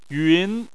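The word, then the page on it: yun: tone 2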